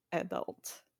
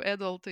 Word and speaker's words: adult: Во-первых, я перед выпуском еще раз посмотрела, как произносится в русскоязычной благосфере э, new adult и young adult. Как-то э, склоняются ближе к ударению на последний слог.